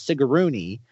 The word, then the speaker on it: Sigourney: And like I said, there's a reason why that shot of Sigourney Weaver, who we've been saying her name wrong the entire time, it's Sigourney, and you have the part with the alien kind of like brushing against her cheek.